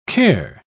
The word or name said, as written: care